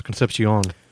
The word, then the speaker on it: Conception: And Concept is pronounced Concepcion. – Concepcion.